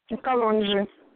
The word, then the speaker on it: Kalonji: I don't want to butcher your last name, so tell me how do you say your last name? – Kalonji. – Kalonji, okay. Thank you.